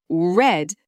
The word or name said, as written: red